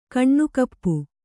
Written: ಕೈನೆಗಪು

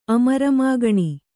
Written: ಅಮರಮಾಗಣಿ

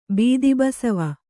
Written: ಬೀದಿಬಸವಿ

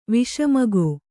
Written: ವಿಷಮಗೋ

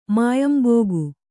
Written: ಮಾಯಂಬೋಗು

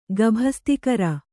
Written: ಗಭಸ್ತಿಕರ

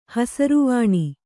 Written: ಹಸಿರುವಾಣಿ

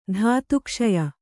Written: ಧಾತುಕ್ಷಯ

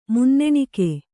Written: ಮನ್ನಣೆಗೈ

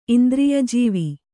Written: ಇಂದ್ರಿಯಜೀವಿ

